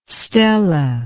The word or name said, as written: stella